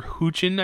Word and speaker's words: Hoechlin: I was going to say, so full disclosure, when I saw all the promotional footage and pictures of Tyler Hoechlin, I think is how you say his last name, as Superman, I thought that was pretty terrible.